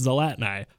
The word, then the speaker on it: Zlatni: And I was like, it's Zlatni, which rhymes with the fat guy.